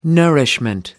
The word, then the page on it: nourishment: American English